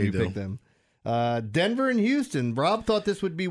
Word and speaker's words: Houston: Houston.